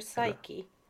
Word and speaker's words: Psyche: Jag tror det är P-S-Y-C-H-E. Psyche. – Eh, killer Psyche. – Psyche. – Okej. Okay. Psyke ja, betyder det.